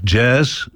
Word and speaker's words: jazz: En die speelde graag jazz, J-A-Z-Z. Dat moest je ook zo uitspreken, jazzmuziek.